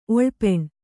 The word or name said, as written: ಒಳ್ಪೆಣ್